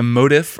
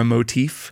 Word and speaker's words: motif: I would say the term for this is a motif or a motif, depending on how you want to pronounce it, spelled M-O-T-I-F.